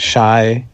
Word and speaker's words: Shai: You just find me at Shai. My name is spelled S-H-A-I, Plonsky, P-L-O-N-S-K-I.